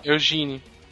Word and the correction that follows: Egwene: É Egwene. – Eu acho que o G é mudo, viu, cara?